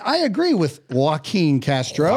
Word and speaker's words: Joaquin Castro: Joaquin Castro. – Joaquin Castro.